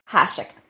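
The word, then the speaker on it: Hašek: Hashik.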